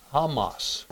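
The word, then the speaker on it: Hamas (חמס): So the word violence is see, kamas. But today it's pronounced or spelled this way, Hamas.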